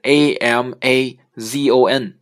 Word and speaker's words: Amazon: 亚马逊的这个名字是发呃拼写是 A M A Z O N，它是一条非呃南美洲的一条河流。呃，这个发音呢是 Amazon，Amazon，它的重音在前面的 A，第一个 A，第一个字母。我们原来经常发成 Amazon，Amazon 啊，其实是不对的啊，应该是 Amazon。